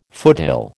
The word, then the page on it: foothill: American English